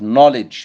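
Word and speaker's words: knowledge: اي كلمه تبدا بكي ان ما ننطقش الكي، زي نو يعني يعرف، نوليدج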